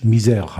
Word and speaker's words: Misair: es gab nur eine, äh, eine Fluggesellschaft in Ägypten, die trug den Namen M-I-S-A-I-R. Die hatten in Frankreich auch nicht besonders viel Glück, weil der ausgesprochen in französisch Misère heißt, das Misère.